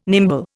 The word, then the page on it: nimble: Indian